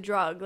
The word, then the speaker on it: drwg: Is it DRWG or the drug? Like, how do I say that? – Drug, but it is actually a Welsh spelling, so you would pronounce it in Welsh, drug, and that would be bad in Welsh.